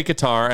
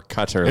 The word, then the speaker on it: Qatar: We say Qatar, and I believe it's like more Qatar.